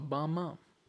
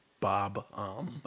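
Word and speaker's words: Bob-omb: But he'll always be there as a bomb mom. Is that how you say it? Bomb mom? – Bob-om. Yeah. For a second, I wasn't sure.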